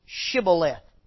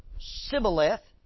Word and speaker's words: Shibboleth: And if he said no, they said to him, "Then say Shibboleth." And he would say Sibboleth because he could not pronounce it right. I don't know if that's northern versus southern Israel or what the deal is, but they couldn't say Shibboleth.